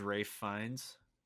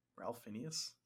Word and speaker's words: Fiennes: How's Ralph Fiennes? Voldemort? – Ralph Phineas? – It's pronounced Ralph Fiennes. – Oh. But it's spelled like Ralph Phineas.